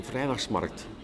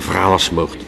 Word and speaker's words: vrijdagmarkt: ja, als je woont op de vrijdagsmarkt, kijk, dat is de Gentenaar die spreekt, en want officieel is het vrijdagmarkt, maar een Gentenaar kan dat niet uitspreken zonder die tussen S. Eh, de vrijdagsmarkt.